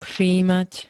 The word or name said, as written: všímať